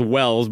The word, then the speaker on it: Welles: But yeah, the singer's name is, his last name is Wells, but it's spelled Welles.